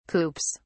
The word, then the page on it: coops: British English